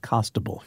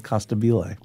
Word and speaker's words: Costabile: He's like, it's pronounced Costable, he said. I always thought it was Costabile.